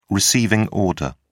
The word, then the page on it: receiving order: British English